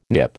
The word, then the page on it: Nip: American English